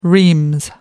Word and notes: reams: British English

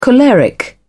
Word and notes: choleric: British English

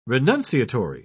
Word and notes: renunciatory: British English

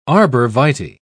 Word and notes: arborvitae: British English

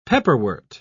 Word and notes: pepperwort: British English

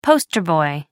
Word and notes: poster boy: American English

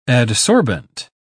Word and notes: adsorbent: British English

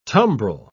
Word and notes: tumbril: British English

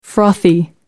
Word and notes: frothy: American English

